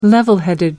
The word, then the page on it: level-headed: American English